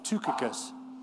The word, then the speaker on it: Tychicus: Now, he starts out by saying in verse 7, all my state that this is pronounced Tychicus, declare unto you, who's a faithful brother and faithful minister, fellow servant to in the Lord.